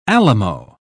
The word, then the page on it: alamo: British English